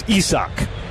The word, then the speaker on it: Isaac: Spelled like the English word Isaac. As in Isaac Collins. Spelled the same way, but this guy's Isaac.